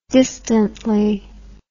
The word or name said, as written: distantly